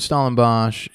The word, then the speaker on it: Stellenbosch: So you're in Stellenbosch. Is that how you pronounce it? – Mm-hmm. – You're in Stellenbosch.